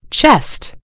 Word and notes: chest: British English

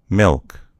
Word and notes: milk: American English

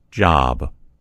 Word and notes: job: American English

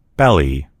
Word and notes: belly: American English

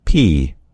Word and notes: pea: American English

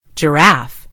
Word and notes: giraffe: British English

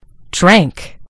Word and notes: drank: British English

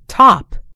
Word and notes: top: American English

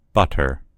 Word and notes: butter: American English

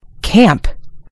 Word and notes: camp: American English

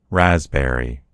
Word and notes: raspberry: American English

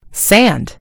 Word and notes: sand: American English

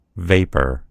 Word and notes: vapor: American English

